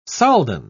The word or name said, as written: soldan